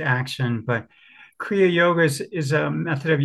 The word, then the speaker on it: Kriya Yoga: then you learned with Chinese masters and later became a Kaya Yoga. Did I say that right? Kriya Yoga? Oh, Kriya Yoga. Yeah.